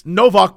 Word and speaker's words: Nowak: And in Poland, it's Novak, but it's spelled N-O-W-A-K, but the W's are V's in Poland.